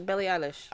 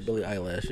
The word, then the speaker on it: Billie Eilish: I always say Billie Eilish. – I say Billie eyelash to piss my daughter off. – Eyelash. – Yeah, Billie Eilish.